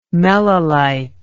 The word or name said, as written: melilite